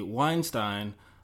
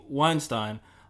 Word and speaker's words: Weinstein: So we know because of Harvey Weinstein. Uh, as a German speaker, I'm always just going to pronounce it that way. So Harvey Weinstein uh, for example, uh, gives you a really good example of how vulnerable women can be.